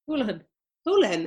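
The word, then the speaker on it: Holohan: How do you say a second name? – Houlihan.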